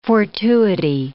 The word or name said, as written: fortuity